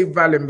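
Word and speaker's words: Wallin: I say Vallin because his name is spelt with a V and they've made everyone put W, but it's Vallin, Otto Vallin. I've done my research.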